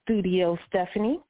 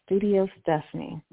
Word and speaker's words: Studio Steffanie: Again, my name, I'm um, Studio Steffanie, and that is spelled S-T-U-D-I-O S-T-E-F-F-A-N-I-E, Studio Steffanie.